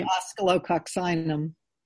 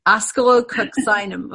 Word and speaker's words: Oscillococcinum: How do you say that one? Acylococcium? – I'd say oscillococcinum. – Oscillococcinum. Okay, I was way off.